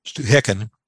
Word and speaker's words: Stehekin: called Stuhekin, and it's spelled S-T-E-H-E-K-I-N, but pronounced Stuhekin.